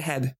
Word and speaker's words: H-E-B: M-E-I-J-E-R. – Yeah, that sounds right. – Okay, the one that's down near you, it's H-E-B. Is that how you say it or is it Heb? – Oh my goodness. No, it's H-E-B and it is...